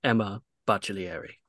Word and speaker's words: Emma Baccellieri: The queen of sodas, the head jerk at the soda fountain, and also she's at Sports Illustrated, I guess, but it's Emma Baccellieri. – Thank you for the full Italian pronunciation of the last name now.